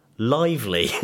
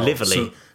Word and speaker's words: Lively: Yeah, so The Shallows is a movie starring Blake Lively. – Well, that's how I would say her name as well. – Lively.